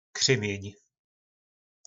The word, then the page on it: Krymjyn: po našimu